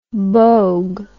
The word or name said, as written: boag